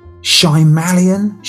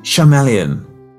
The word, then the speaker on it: Shyamalan: Night Shyamalan. Shyamalan.